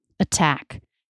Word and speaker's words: attack: So if we're talking about beginning a stressed syllable, then it's not a flap T. That is a true T. Attain, attack.